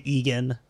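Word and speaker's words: Eagan: So in St. Louis, a detective named John Agan, spelled like Reagan, but without the R, E-A-G-A-N. – I would say Egan. – Egan? – I would say Egan, probably.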